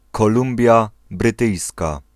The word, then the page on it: British Columbia: /ˈbɹɪt.ɪʃ ˌkə.lʌm.bi.ə/